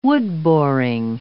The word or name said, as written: wood-boring